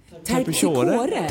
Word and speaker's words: Terpsichore: Terpsichore? – Terpsichore.